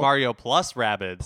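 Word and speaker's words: Mario + Rabbids: Mario plus Rabbids. – Found out that's actually how it's pronounced.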